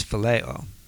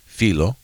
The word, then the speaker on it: phileo: So this word is not agape, it's not eros, it's not storge, it's phileo. – Oh, which means philo, which is the right way to say philo.